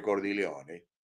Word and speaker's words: Cordileone: El arzobispo de San Francisco, que se llama Salvatore Cordileone, o para decirlo como se pronuncia en italiano, Salvatore Cordileone,